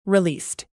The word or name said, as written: released